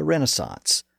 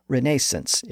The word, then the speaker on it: Renaissance: It was the movement that we know today as the Renaissance, or Renaissance if you prefer the British pronunciation.